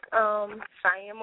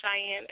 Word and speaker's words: Shianne: You can find me on Facebook, Cheyenne Monique, Cheyenne, S-H-I-A-N-N-E, Monique, M-O-N-I-Q-U-E.